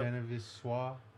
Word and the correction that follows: je ne sais quoi: Je ne sais quoi. – Je ne sais quoi.